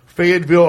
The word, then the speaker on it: Fayetteville: Fayetteville, Arkansas, at University of Arkansas.